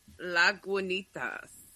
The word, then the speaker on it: Lagunitas: He says it's not gua, it's ga. – It's not qua. It's not a Q U or a K. It's no. I was doing a G and a U. – It makes sense. – L O L O G. Log. Lagunitas.